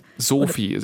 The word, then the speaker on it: SOOFI: SOFI, S-O-O-F-I, haben wir gelernt, wird ausgesprochen, bisschen wie der Name, aber nicht genau.